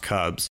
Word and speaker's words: CVBZ: All right, so Cubs, the first question that, of course, we as the listeners want to find out is you have a unique spelling for your name, CVBZ, and it's pronounced Cubs.